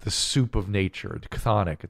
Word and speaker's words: chthonic: Look up the word chthonic. C-H-T-H-O-N-I-C. C-H-T-H. – It sounds like the word. – No. C-H-T-H.